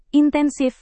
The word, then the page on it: intensif: indonesian